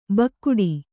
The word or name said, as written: ಬೇಗಡೆ